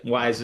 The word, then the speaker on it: Wyze: Is that how you say it, though? Wyze camera? – Yep. Wyze is the company.